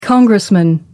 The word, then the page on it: congressman: American English